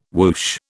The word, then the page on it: woosh: British English